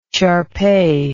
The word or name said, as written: shar pei